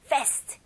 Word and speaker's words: fast: 네, 여기 A 발음이 조금 길게 해주시면 더 좋아요. 그래서 fast 이게 아니고 fast, fast 빠른 이라는 뜻을 가지고 있죠.